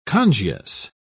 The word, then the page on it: congius: British English